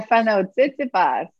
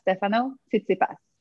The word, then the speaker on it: Tsitsipas: Stefano Tsitsipas. – What is that? How did you say that? You can say that name? – Of course. – Say it again. – Stefano Tsitsipas.